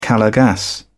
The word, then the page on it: calor gas: British English